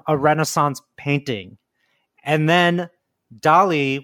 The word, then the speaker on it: DALL-E: Kind of like Salvador Dolly, but spelled like Wally with a D. So it's Dolly. – How do you spell it? – So D-A-L-L-E, Dolly.